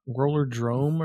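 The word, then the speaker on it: Rollerdrome: They're saying a game is coming out in August called Roller Dome. – Yeah. Yeah, that one grabbed me. – Roller Drome or something? – Drome. Yeah. Sorry, I said it wrong.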